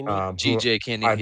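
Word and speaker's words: GJ Kinne: So Texas State's coach is GJ Kenny, or however you pronounce his name. – Kenny. GJ Kenny. Yeah.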